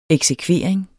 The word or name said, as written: eksekvering